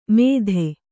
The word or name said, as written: ಮೆದೆ